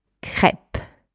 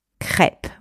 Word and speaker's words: crêpe: This final I in that particular word is silent. So you do not pronounce it. Crêpe. Crêpe, right? You basically pretend that the I is not there at the end, right? So altogether, we have crêpe. Crêpe. Et voilà, you have just pronounced the word crêpe the French way.